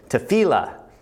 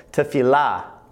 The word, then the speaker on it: tefillah: And I was corrected by my Hebrew professor this last week that I've been saying it wrong, so I apologize. I've been saying it to you, teaching it to you as tefillah, and I was putting the accent on the middle syllable but the accent should be on the last syllable, so it should be pronounced more like this tefillah, tefillah.